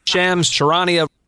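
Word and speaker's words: Shams Charania: Is that how we pronounce it? – Sham Sharania? I think so, no? – Sharania. – There you go. – Really? – Shams Sharania. – Oh. – No, I think. – Well, who's right? – I think.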